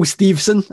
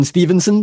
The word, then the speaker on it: Steveson: Gable Steveson. I keep pronouncing his name wrong. I keep going Stevenson because obviously that's just the way you would. So Gable Steveson apparently has held some talks with people for WWE.